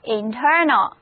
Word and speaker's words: internal: 我们再来看其他含有 inter 词根的单词，比如说这个 internal，重音放在第二个音节上， a 字母不发音，跟我读这个单词就是 internal。